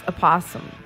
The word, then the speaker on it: opossum: It's what? Pronounced opossum. – Opossum? A possum? – The O is like, it's like this little opossum.